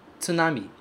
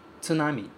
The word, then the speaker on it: tsunami: Pense na palavra tsunami. Tsunami. Tsunami, ok? Com três sílabas. Tsunami. E não tsunami.